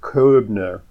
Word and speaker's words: Koebner: There's a well-known phenomenon called the Koebner phenomenon. It's spelled K-O-E-B-N-E-R. Only dermatologists know about this. Yeah, Koebner. Right, because I studied German, so I can.